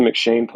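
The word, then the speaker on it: M'Cheyne: Yeah, it is M-C, it's Irish. Let me make sure that I'm saying it right. Yeah, it's M-C-H-E-Y-N-E. – Got it, okay. – It's the McShane Plan, and it's just a really good plan, and it's a great addition to my miracle morning.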